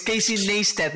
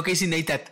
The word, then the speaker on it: Casey Neistat: Aprende, aprende a pronunciarlo bien, güey. Es Casey Neistat, no Casey Neistat.